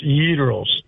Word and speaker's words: gyros: The proper pronunciation for gyro, whatever. A Greek restaurant owner jumped on my butt one time 40 years ago. It's pronounced gyros. That's the pronunciation. – Gyros. You got to roll the R even.